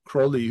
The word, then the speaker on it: Crowley: And he was involved with Thelma, which was a demonic religion that started with early 1900s with Aleister Crowley, who liked to pronounce his name cruelly, he called himself the Beast.